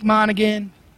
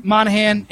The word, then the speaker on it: Monaghan: Dominic Monaghan. That's how you say his name. – Monaghan? – Monaghan.